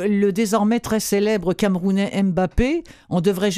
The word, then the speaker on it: Mbappé: C'est pour ça que normalement, les journalistes français, quand ils appellent Mbappé, nous, en tant que Camerounais, on dit non, le M ne se prononce pas. Parce que, ça vous fait Mbappé. Rigoler, quoi. – Donc, Mbappé.